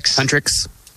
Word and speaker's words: Huntrix: but Huntrix.